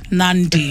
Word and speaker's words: Nadi: in Nadi Fiji, it's Nandi.